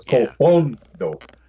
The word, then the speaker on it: Col Fondo: It's spelled Colfondo with the emphasis on the O, so it's Colfondo.